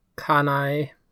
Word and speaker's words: Kanae: I will point out one example of this because it's the first one, but Kanae, name that I'm really having trouble pronouncing, but I think it's Kanae. – Kanae. It's like Kanae. – Kanae, yeah.